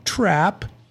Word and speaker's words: Trapp: You know, that's me, by the way, Samuel Trapp. T-R-A-double-P, by the way, when you're spelling that. Like Mousetrap, but two P's is how I like to say it.